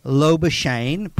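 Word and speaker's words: Labuschagne: But concussion symptoms can flare and linger, which would make him in doubt for that game, which would mean Lobashane, pronounced like champagne, might come into the side, meaning there'd be four debutants.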